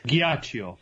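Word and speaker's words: Ghiaccio: how did he pronounce his name? Giaccio? – Giaccio.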